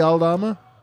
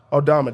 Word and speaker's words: Aldama: How do you say his name? Aldama? – Santi Aldama. – Aldama. There you go, actually.